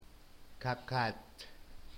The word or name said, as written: kakat